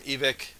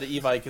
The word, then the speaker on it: Evike: you can all get all this from Evic. I was like, oh shit, we have been saying it wrong. So I tried to switch, but it sounds so weird. – Yeah, saying Evic.